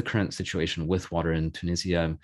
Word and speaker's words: Tunisia: So what is the current situation with water, both in Tunisia, or sorry, actually, before I say that, how shall I pronounce the name? Because in the UK, they say Tunisia, in the US, they say Tunisia. – Both works, whatever you feel comfortable pronouncing. – What do you say? – Tunisia. – Tunisia. – Yeah. – Then I'll say that. Okay.